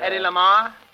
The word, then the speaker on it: Hedy Lamarr: one like Hedy Lala, Hedy La... – Hedy Lamarr? – How do you spell it?